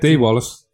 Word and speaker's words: Dee Wallace: Dee Wallace? Am I saying that right? – Dee Wallace. – Dee Wallace, sorry.